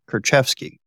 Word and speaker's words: Kurczewski: yeah, she her name is hard to pronounce, and my tongue is not working. Jessy Kurczewski, I think, is how they pronounce it.